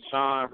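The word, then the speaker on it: Shaun: My Twitter name is Sean Red Rum, SHAUNREDRUM.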